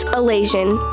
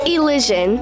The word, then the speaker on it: Elysian: So last week's word of the week was Elasian. I have to side with Remy. It is a real word. However, it is pronounced Elysian, but it would be a very Remy thing to do, to pick a word that's not real.